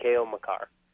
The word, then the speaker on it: Makar: Is it Makar or Maker? – Maker. Kale Maker. – Maker. – Or Makar. It's technically Kale Makar. – That's the pronunciation I've been hearing is Makar.